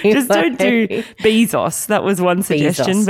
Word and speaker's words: Bezos: According to the man himself, it's Bezos, not Bozos, not Bezos, not Bezos.